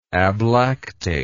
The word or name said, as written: ablactate